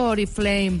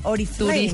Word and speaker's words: Oriflame: Eh, vamos a hablar con Jenny Light, vamos a hablar de belleza con Oriflame o Oriflame, nunca entendido cómo se dice. Yo digo Oriflame. – Oriflame, ¿no? O será Oriflame, Oriflame. Gracias.